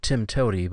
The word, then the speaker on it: TMTOWTDI: It's a programming acronym, and it's pronounced Tim Toady, but the actual letters are T M T O W T D I.